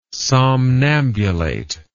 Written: somnambulate

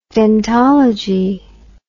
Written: dentology

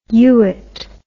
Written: yuit

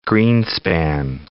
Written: Greenspan

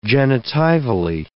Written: genitivally

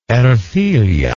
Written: ercilla

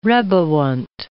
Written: reboant

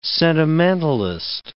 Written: sentimentalist

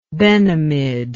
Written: Benemid

